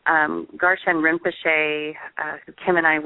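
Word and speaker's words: Garchen: It's called the Garshan Institute in Chino Valley, Arizona.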